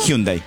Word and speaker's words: Hyundai: Hyundai.